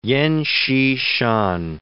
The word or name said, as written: Yen Hsi-shan